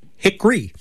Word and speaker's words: hickory: It's not three syllables. It's pronounced two syllables. So I guess hickory.